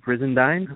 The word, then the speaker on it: Brisendine: We have Steve Brizendine. Is that the right way to say it, Steve? I never actually say your last name. – That's the right way to say it, yeah.